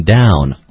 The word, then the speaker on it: down: there is a 声调 it's like it's in the fourth tone, down, right?